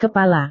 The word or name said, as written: kepala